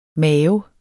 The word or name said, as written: mave